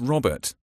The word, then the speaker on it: Robert: It's Robert. I spell it R-O-B-E-R-T.